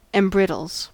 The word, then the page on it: embrittles: American English